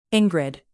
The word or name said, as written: ingrid